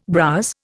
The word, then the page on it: browse: Indian